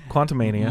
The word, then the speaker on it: Quantumania: But what are your thoughts on Ant-Man Quantumanium? – Quantumanium? – I don't know. I've said it wrong like 6 times, so just go with it at this point, okay? Quantumania.